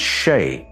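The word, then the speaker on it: sídhe: It's pronounced she, but it's spelt very close to sede, and it refers to the interaction between the spirit world and the material world.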